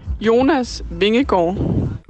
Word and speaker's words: Jonas Vingegaard: I mean, there was a fantastic moment where when he turned around looking for help, the rider on his wheel was Jonas Vingegaard. Sorry for the pronunciation. Here's Emma Norsgaard with the correct pronunciation. – Jonas Vingegaard.